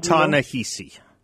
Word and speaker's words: Ta-Nehisi: How do you say his first name? – Ta-Nehisi. – Ta-Nehisi.